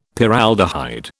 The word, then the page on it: Paraldehyde: British English